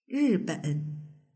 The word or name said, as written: riben